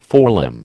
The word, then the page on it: forelimb: American English